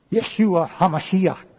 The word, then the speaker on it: Yeshua HaMashiach: Yeshua HaMashiach is the way it's pronounced in the Hebrew language.